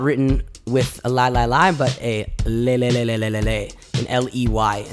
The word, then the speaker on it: lai: Now in America, when we sing nigunim, often we use the syllable li-li-li, right? Not L-I-E, but L-A-I, just as a nonsense syllable, li-li-li.